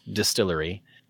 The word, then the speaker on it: DSTLRY: That is, it's pronounced Distillery, but it is spelled without any vowels. D-S-T-L-R-Y, Distillery.